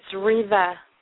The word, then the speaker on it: Reva: It's Reva. – How do you spell that? – R-E-V-A.